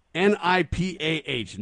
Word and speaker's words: Nipah: India is moving fast to contain the spread of, and I don't know how to say this virus, NIPAH, Nipah. They say the Nipah virus, reports CBS News.